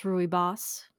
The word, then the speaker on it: rooibos: So let's talk about one of my favorite types of tea, rooibos. Okay. Have I talked about rooibos teas yet? – No. – Also pronounced rooibos, depending on who you are. – Rooibos, yes. So many ways to pronounce it.